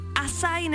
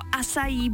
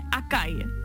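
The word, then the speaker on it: açaí: Asaj nebo asají bowl a ne akaj. Někdo říká acai bowl, tak je to acai.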